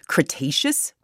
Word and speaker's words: Cretaceous: All right, for our next story, our third story, we're heading back tens of millions of years ago to the, okay, let me see if I say this right, Cretaceous period? – Nailed it.